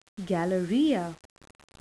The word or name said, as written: galleria